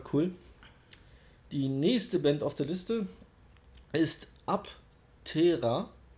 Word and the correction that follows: Aptera: Aptera. Wie schreibt man das? A-P-T-E-R-A.